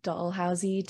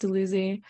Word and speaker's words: Dalhousie: See, one time I said Dollhousey to a Brantford local and they laughed at me because apparently it's pronounced Dalhousie.